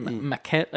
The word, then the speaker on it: maquette: It was like a giant like I guess like a maniquette or something. Maquette, I think is the pronunciation.